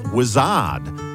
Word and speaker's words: Wizzard: But it is time for a 70s triple play, and right in the middle of this one is the English band who spelt their name oddly, hoping that people would pronounce it how they'd spelt it. W-I-Z-Z-A-R-D. Roy Wood, the founder of the band, hoped that we would all say Wizard, but nobody ever has.